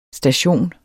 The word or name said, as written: station